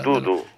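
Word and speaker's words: Dudù: Che poi è Dudu, Non offendere il cane che è Dudu, con l'accento sulla U, Dudu. – Questa è la pronuncia esatta, così dicono.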